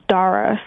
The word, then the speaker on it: Dara: It's Dara, so it's like with the D, as in dog. So, Dara.